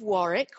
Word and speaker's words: Warwick: So I am, as you say, at the University of Warwick. We don't pronounce the second W in British English.